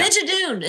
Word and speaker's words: Schmigadoon: Yeah, I think it's Smid. – Smidgen. Yeah. Schmigadoon. It's Schmigadoon. Schmigadoon. – That's what it is.